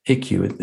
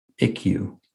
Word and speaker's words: Ikkyu: But the quote, and his name was, if I'm pronouncing it correctly, is Ikkyu. Ikkyu. It sounds like Nikkyu without the N. Ikkyu. I-K-K-Y-U.